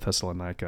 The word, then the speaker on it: Thessalonica: I think, many people call it Thessalonica. I actually discovered it's actually pronounced Thessalonica, which is kind of interesting.